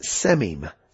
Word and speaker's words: sememe: Now, in closing out this first section here, we're going to talk about a very interesting grammatical term that's pronounced semim. Semim, spelled S-E-M, and that's the crucial part of it. S-E-M-E-M-E.